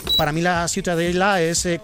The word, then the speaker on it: Ciutadella: ¿cómo se pronuncia? – La Ciutadella. – Ciutadella. Bueno, pues Ciudadela también en castellano. – Ciudadela, sí. – Pues eso, ha quedado clarísimo. Pero, ¿para qué se meten? – ¿Tú cómo lo pronuncias? – Ciutadella. – Muy bien.